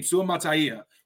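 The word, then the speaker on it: Suamataua: I finally, thanks to the beast for Dane Brugler, I know how to say his name, Suamataya, which makes way more sense than what I was saying, American as hell, Suamataya.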